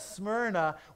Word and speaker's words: Smyrna: And I got an email, and it just said S-S-S-S-S-S-S, so like S, all right? And then Mer, and then Na. And I will never say that wrong again, but Smyrna was a powerful city as well.